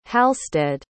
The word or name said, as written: halstead